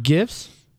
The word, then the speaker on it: GIF: Yeah, it's pronounced GIF. Right. And no, but you can learn how to make GIFs on there. – It's a G. It's not a J. GIF is with a J in it.